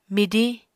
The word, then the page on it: midiñ: Apatani